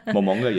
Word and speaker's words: Momonga: So yeah, he's sitting at his massive round table and he has a slime of a friend who calls him Momongo. So now we know that the character's name is Momongo. Momonga? Am I saying that right? I just want to make sure. – Yeah. Momonga, yeah.